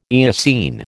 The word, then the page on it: Eocene: American English